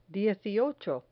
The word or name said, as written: dieciocho